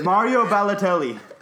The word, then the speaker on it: Mario Balotelli: Mario Balotelli.